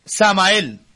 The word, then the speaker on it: Samael: Samael. – Sí. – Samael, se escribe uh-huh. S-A-M-A-E-L.